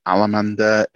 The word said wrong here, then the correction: Amendola: It's Matt Amendola. Sorry, Matt Amendola, I believe, is going to get the starter.